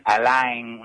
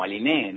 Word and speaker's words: Aline: Es mapuche el nombre, aclaro, porque muchas veces cuando nos hacen notas o lo que sea, se lee Aline y estamos tan tranquilizados todos que la pronuncian Alain, una cosa así, y en realidad, sí, malísimo. Pero bueno, hago la aclaración porque lo amerita, digamos, es mapuche, digamos, como Aline, ¿no? – Claro, justamente.